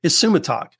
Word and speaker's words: Isumataq: Now, this word is from the native Inuit Alaskan language, and the word is ISUMATAQ. So you saw the title for today's episode. That word is pronounced ISUMATAQ.